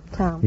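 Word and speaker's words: Thom: Tom. – The H is silent, eh? – That's right. – Like in herring, eh? – Mm-hmm.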